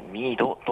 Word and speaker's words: MEEDO: MEEDO と書いてミードと読みます。はい、ここでですね今日、夕方から夜にかけてですね、ちょっとミード町開き夜市というイベントがありますので、せっかくオープンしたばかりの施設のミードさん、そしてですねこのイベントについて今日はレポートしたいと思います。